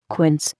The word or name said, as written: quince